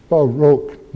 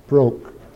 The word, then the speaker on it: Baroque: Notice two syllables, Baroque, not one, broke.